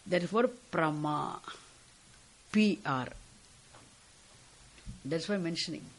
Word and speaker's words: Prama: Prama means knowledge. The word Prama, we'll be very careful in pronouncing it. We should not write it Brahma. Brahmaha, confusion. Prama, knowledge.